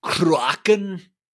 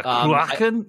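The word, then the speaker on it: Cruachan: I think you have to say it something like Kruachan. – Yeah, Kruachan.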